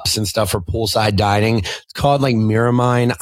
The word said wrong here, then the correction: melamine: But then Melamine.